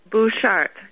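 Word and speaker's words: Butchart: We went out to the island, Vancouver Island, and we saw, what was it, Beutrick Garden? Did I say that right? – Bouchard Gardens. – Oh, Bouchard, yes.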